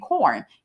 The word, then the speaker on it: Quorn: The company I just got through talking about named Quorn, Q U O R N. I got to spell it because it sounds like corn, C O R N, but that's not it.